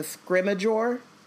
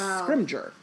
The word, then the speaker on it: Scrimgeour: I don't know why I put the E before the G, because I always thought of it as a scrimmageur. Scrimmageur, but it's Scrimgeour. – Mm. – There's no E there. There's no, like, extra syllable. It's syllable? – Yeah. – Monosyllabic. Yeah. There's no extra syllable there.